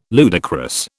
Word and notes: ludicrous: British English

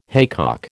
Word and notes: haycock: American English

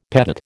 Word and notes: pedate: American English

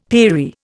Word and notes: peri: Indian

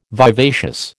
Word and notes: vivacious: American English

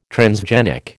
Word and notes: transgenic: American English

